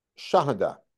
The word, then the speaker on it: shahada: He spells it with a shin. It's a sin, right? But you know the word sahada, as a witness, right? How do you say that in Arabic? Shahada.